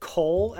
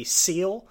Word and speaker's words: coel: I think we should clarify, though, that most people pronounce it coal as short for C-O-E-L in the game, but they're wrong. If they really want to say it correctly, it'd be seal, but that's just...